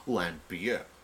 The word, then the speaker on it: Lambert: Like Christopher Lambert doesn't pronounce his name Christopher Lambert. It's Lambier.